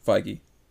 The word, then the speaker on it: Feige: Kevin Feige confirmed that I'm sorry? – Feige. – Feige? – Yeah. – I didn't know that. Yeah. Kevin Feige? Oh, yeah, that's how you pronounce that name.